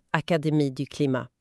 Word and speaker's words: Académie du Climat: Académie du Climat, perfekt franskt uttal.